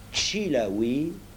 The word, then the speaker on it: Chilaoui: En appelant le roussi Chilaoui, avec l'accent tonique sur la première syllabe, et l'autre Chicani, avec l'accent sur la dernière syllabe, on apprit à chacun à connaître son nom et tout finit par s'arranger.